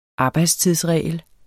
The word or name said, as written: arbejdstidsregel